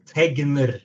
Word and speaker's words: Tegner: Tegner, you would say it.